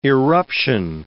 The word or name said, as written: irruption